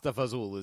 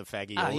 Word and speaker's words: fagioli: Pasta fazool is bean soup. – Yeah, it's a soup. – The white bean soup. Even though it's spelled fagioli, which is the first time I ever ordered that soup, I ordered pasta fagioli.